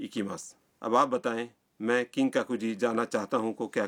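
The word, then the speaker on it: کنگ کا کجی (金閣寺): مثلاً آپ کیوتو کے کنگ کا کجی مندر جانا چاہتے ہیں.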